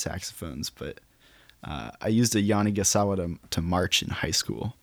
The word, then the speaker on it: Yanagisawa: Yamaha or Yanagisawa? Did I say that right, by the way? Yes. I got the nod.